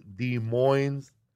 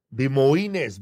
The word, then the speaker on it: Des Moines: I'm coming to Des Moines, Iowa, at the end of July.